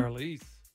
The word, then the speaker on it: Charlize: Charlize. – Oh, Charlize Theron.